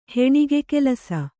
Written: ಹೆಣಿಗೆಕೆಲಸ

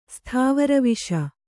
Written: ಸ್ಥಾವರವಿಷ

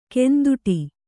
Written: ಕಂದೊಡಿ

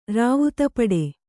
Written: ರಾವುತಪಡೆ

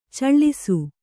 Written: ಚಾಳೀಸ

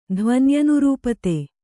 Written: ಧ್ವನ್ಯನುರೂಪತೆ

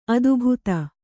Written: ಅದೆಬದೆ

